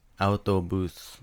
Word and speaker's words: autobus: Autobus. – Autobus. Bus. – Autobus. Put the accent on the to.